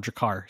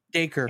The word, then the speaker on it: Dacre: Dacre. – And or both. What? What is it? It's pronounced just like the unit of measurement, acre, but with a D at the front.